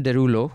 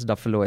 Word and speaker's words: Derulo: So Rajshri said there's this person, I know it's a famous name, Jason Derulo, who I mispronounced upstairs. I called him something else, Duffalo, I think.